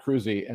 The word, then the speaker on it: Kroese: But on my books, I'm Robert Kroese, and it's spelled K-R-O-E-S-E. So you would never guess it's Kroese.